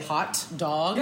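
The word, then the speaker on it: Haute Dog: It's spelled H-A-U-T-E, like haute couture, except for they say it, Hot Dog.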